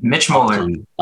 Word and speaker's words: Mitch Moeller: You did, Mitch Moeller.